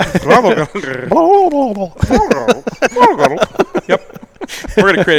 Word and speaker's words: Throttle Grotto: I started Throttle Grotto.